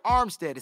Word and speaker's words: Armstead: His name starts with the A, so I need to pronounce the A. Eric Armstead is at $12.5 million.